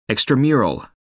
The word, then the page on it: extramural: American English